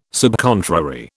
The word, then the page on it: subcontrary: British English